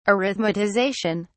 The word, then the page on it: arithmetization: British English